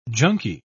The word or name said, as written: junkie